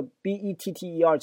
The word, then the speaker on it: better: 说在 1860 年呢，a better plan 一个更好的计划，这个 b e t t e r，请注意它的发音啊，你可以读成 better。为什么两个 t 可以读成 d 啊？